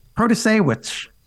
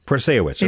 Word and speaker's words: Protasiewicz: Protasiewicz. – Protasiewicz, okay.